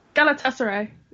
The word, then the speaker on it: Galatasaray: Galatasaray.